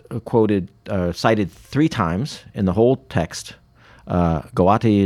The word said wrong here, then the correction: Gowdy: is, am I saying it right? Gawati? – Oh, I always thought it was Gowdy. – Gowdy. Yeah. Okay, sorry.